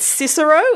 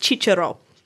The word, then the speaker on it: Cicero: In the same way that Cicero is actually supposed to be pronounced Chichero.